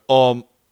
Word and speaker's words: Amon: The A in Amon is pronounced like the U in the word bus, and the O like an O in the word oven. – So that's... – So, Amon.